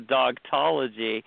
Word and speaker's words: Dogtology: How did you get into dogology? Am I saying it right? – So dogtology. – Dogtology, all right.